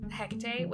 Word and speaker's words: Hecate: In like, if you're in like witch circles, which is Hecate, which is pronounced the way I just said it, Hecate, as far as I know, is pronounced Hecate.